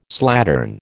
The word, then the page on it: slattern: American English